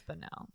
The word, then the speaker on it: Bonnell: I hope I'm saying that right. Right. Bonnell. I don't know. – I think it's Bonnell.